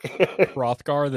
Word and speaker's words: Hrothgar: Mr. Cultural Anthropologist, how the fuck do you pronounce that? Put you to work. – Hrothgar the Dane? – There you go. – Hrothgar, there we go.